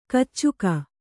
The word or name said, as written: ಕೀಚಕ